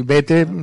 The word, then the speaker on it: Bethe: B-E-T-H-E. – Bethe. Vale. No, por si alguien lo está escuchando y se quiere aprender, Bethe, suena Bethe.